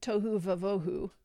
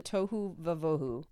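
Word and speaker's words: tohu va-vohu: Tohu vavohu. – That's beautiful. – It rhymes, which Hebrew doesn't usually do. – Tohu vavohu.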